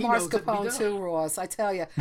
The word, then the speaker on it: mascarpone: Yeah, technically, it's exactly how you said it. It is mascarpone. It's like a little ne.